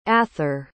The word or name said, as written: athar